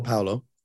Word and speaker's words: Paolo: Yeah, Paolo.